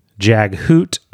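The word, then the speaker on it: Jaghut: And we got Tyst Andy, Kerald Gillane, Jag Hoot, not a silent T, Talan Amass.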